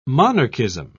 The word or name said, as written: monarchism